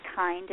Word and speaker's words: Kind: and my name is spelled V-I-K-I, and then K-I-N-D, and my last name is Kind, and I am Kind.